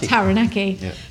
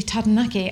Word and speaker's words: Taranaki: Before we move on, let's just talk about the pronunciation of Taranaki, okay, because it is spelt Taranaki, and we, up until 4 months ago when we started doing YouTube, always called it Taranaki. Because that's what everyone else calls it. And then someone said, you know, we heard a Māori person pronounce it properly, and they said, oh, it's actually Taranaki, and it sounds so beautiful when it's pronounced the way it should be.